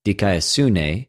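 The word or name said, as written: δικαιοσύνη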